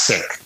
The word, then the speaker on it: Sikh: And by the way, it's pronounced sick. Like I'm not feeling well. Sick.